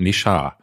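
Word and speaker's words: Ne Zha: Und die Zahlen, die da aus China kommen für Nesha 2 oder Nesha, wurde mir gesagt, Nesha. Würde das wohl am besten ausgesprochen werden.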